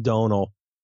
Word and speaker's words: Domhnall: It's pronounced Donal. – Donal. – Okay, so Donal like Donal Logue? – No, like the word, tonal, but with a D.